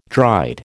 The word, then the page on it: dried: American English